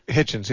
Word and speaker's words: Hitchens: Hitchens, yeah.